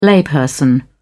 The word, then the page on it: layperson: British English